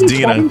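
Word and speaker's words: Deana: I think it's Dina. 17? Dina, Deanna. I put an extra syllable in there.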